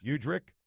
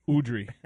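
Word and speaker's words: Udrih: Do you trust Beno, and are we calling him Beno because we're afraid of that last name that's spelled differently than it's pronounced? – Udrick. – Udry. – There you go. There it is.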